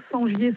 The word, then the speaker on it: Sengier: Sur mon nom, Sangier Sandrine. Et il y a tout... – Sangier, ça s'écrit comment ? – Alors, S-E-N-G-I-E-R.